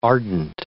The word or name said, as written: ardent